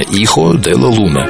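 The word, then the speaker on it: Hijo de la Luna: И даже иногда правильно произносят ее испанское название «Ихо де ла Луна».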